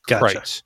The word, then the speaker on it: Kreuz: So in Lockhart, there is Smitty's, Black's, and a place called Kreitz, but it's spelled K-R-E-U-Z. It's a German name. And it's pronounced, they said it's like kites, but with an R in the front, right? So it's Kreitz.